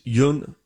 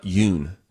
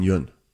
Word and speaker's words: Yeun: He apparently says he told Conan back in 2016 that everyone had been pronouncing his name wrong. It's it's Yun Yun, not Yoon. Stephen Yoon. It's spelled Y E U N. So we go, oh, Stephen Yoon. It's Stephen Yun.